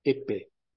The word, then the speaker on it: Eppé: o Georges Eppé. Se escreve a Paix, né? A pronúncia em francês é Eppé.